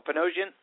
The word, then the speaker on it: Panosian: I want to say a big thank you to Ken Panosian. Is it Panosian? Am I saying that right, Ken? I've done several variations on the O. Panosian? – You got it right at the end.